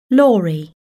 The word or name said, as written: lorry